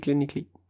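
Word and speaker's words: Llanelli: Et derrière, après, on a donc les Clanecli, les Scarlett de Clanecli, parce que ça s'écrit Lelani, mais ça se dit Clanecli.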